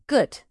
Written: gut

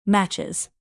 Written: matches

